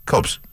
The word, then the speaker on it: copse: Copes. Cope. But it's not C-O-P. – It's spelled C-O-P-S-E. Copes.